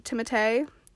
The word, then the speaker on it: Timothée: I'm pretty sure that's actually how you say his first name. Timothée or something like that.